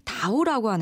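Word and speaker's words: 다오 (倒): 그 이유가 중국에서는 거꾸로 도자라는 뜻의 단어를 다오라고 하는데 네. 도착할 도자 다오랑 발음이 같아요.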